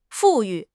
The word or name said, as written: 富裕